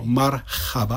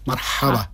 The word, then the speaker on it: marhaba: Taky není to marhaba, ale ha, marhaba.